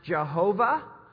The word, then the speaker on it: Jehovah: It can be pronounced Jehovah if you provide three vowels between the consonants, or it can be pronounced Yahweh.